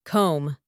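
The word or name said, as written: comb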